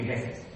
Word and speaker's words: Jefe: So instead, when I took Spanish in school years ago, my name, was pronounced the J. It's like an H. And so it's pronounced different. She said, "Okay, your Spanish name will be Jefe.